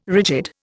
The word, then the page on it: rigid: Indian